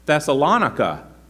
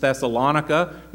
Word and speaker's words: Thessalonica: They may have pronounced it Thessalonica. There are two accepted pronunciations. Thessalonica may have been her pronunciation. We normally refer to Thessalonica as Thessalonica or the Thessalonians, but he named the city for his wife, Thessalonica or Thessalonica.